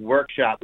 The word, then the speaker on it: WRKSP: Yeah, it's actually pronounced workshop. We took the vowels out.